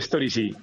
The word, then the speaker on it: Storysy: Storysy, eso Storysy, es como historia, vale.